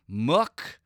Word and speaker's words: Mook: But I am loving the starting lineup of Reggie Jackson, Amir Coffey, Mook, rhymes with book, look at the shot he took, Mook Morris, okay?